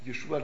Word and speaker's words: Jeschua: Also ich bin, nur ganz kurz nochmal, ich benutze den Namen Jeschua und das rutscht mir mal raus, weil Jeschua, den gibt es seit 3500 Jahren nachgewiesen, wird er so ausgesprochen und Jesus,